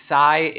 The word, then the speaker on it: Psy: Psy is a good way to it. You know, it's yes and in Spanish. C.